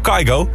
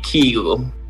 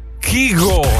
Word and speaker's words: Kygo: Oh ja, ik zeg wel Kygo. We blijken het al die tijd verkeerd uitgesproken te hebben. Misschien wel even handig om een keer aan te passen. Het is eigenlijk... – I guess it's just like the Norwegian pronunciation would be Kygo.